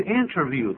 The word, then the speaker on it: interview: 但在这里呢，是有一个 a job interview，这个 interview 的重音在第一个音节。 – 没错，interview。 – 很多人会念成 interview，对，那不对，应该念 interview。